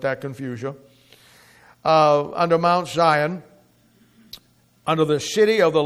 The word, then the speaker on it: Sion: It's spelled S-I-O-N, Zion, but it's Zion.